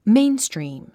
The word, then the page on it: mainstream: méinstrìːm; メインストリーム